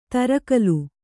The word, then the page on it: ತರಕಲು: tarakalu